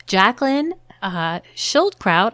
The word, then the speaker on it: Jacqueline Schildkraut: We have a really thought-provoking and fascinating guest with us today, Jacqueline Schildkraut.